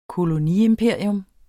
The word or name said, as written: koloniimperium